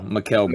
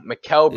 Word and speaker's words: Mikal: How do you pronounce his first name? Mikal Bridges. – Mikal Bridges.